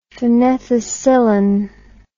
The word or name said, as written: phenethicillin